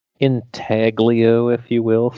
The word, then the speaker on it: intaglio: Intaglio, which is, if you were to say it phonetically, comes out as intaglio. it's an Italian word, so the G is silent.